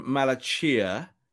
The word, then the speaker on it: Malacia: I'm going Malacia. It's the Chia bit that everyone's going. Is it Sia? I'm going Malacia. Someone from Feyenoord said that's the correct pronunciation, so I'm going with that.